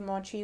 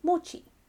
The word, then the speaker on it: mochi: Around the 18th century, people began to call it mochi. So there's a difference in saying it. – Pronunciation. Yeah, okay. you you lengthen the e, so it's mochi and mochi.